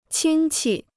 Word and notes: 亲戚: qīn qi

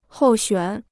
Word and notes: 候选: hòu xuǎn

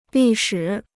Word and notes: 历史: lì shǐ

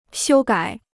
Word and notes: 修改: xiū gǎi

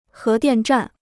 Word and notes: 核电站: hé diàn zhàn

